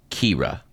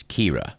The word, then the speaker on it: Kyra: Actress Kyra Sedgwick actually pronounces her name Kira. So if you've been pronouncing the name of Kevin Bacon's wife as Kira or Kyra, you should stand corrected. It's Kira.